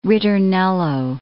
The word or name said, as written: ritornello